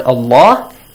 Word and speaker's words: Allah: The first one is about the pronunciation of the word Allah. It's pronounced heavily if there is a fatha or a dhamma sound right before. إِنَّ has a fatha, Allah, so there is a fatha before Allah, pronounce it heavily.